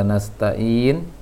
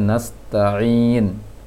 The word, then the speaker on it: nasta'in: Kembali ke koresian yang sebelumnya dari Budi, baru teringat dari ingatan Ana pengucapan ain tadi. Dari pengucapan ain dari bacaan Budi kurang, masih agak terarah ke hamzah tadi bacaannya. Bacaan Budi Hondoyo bacaan tadi nas iyyaka na'budu wa iyyaka nasta'in, seharusnya nasta'in untuk Budi.